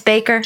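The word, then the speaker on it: Bakker: Jim Baker. – It's Baker. – Baker and Tammy Faye. Of course I know that. – I recognize the name Tammy Faye. – Yes. – All right. Jim Baker and his wife, Tammy Faye, led an...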